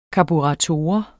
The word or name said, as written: karburator